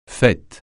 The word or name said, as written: faîte